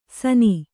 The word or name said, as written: ಸಾನಿ